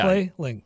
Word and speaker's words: Clay Link: My name is two syllables. – Yeah, Clay Link. – Yeah, you can't. – So you can't mess it up.